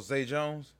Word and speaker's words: Zay Jones: Zach Jones, ain't that his name? – Oh, Zay Jones? – Zay Jones, say the name wrong, yeah.